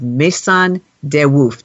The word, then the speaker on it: Maison de Woof: Welcome Susan McGuire, owner, founder, and designer of Maison de Woof. Did I say that right, Susan? – Perfect.